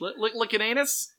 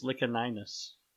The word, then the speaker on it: Licinius: That's how it's spelled, but there's that extra I in there, so I think it's Licinius. Or Licinius, if you want to pronounce it with the soft C. – You want to listen to my what now? – Right, exactly. But I am a hard proponent of the hard C in Roman pronunciation.